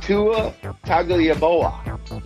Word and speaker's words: Tua Tagovailoa: Tua Tagovailoa.